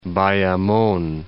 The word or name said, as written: Bayamon